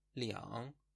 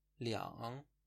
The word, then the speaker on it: 两: عدد دو موقع گفتن زمان به شکل لیان تلفظ میشه و دیگه به شکل آر خونده نمیشه لیان با تون